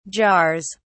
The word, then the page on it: jars: British English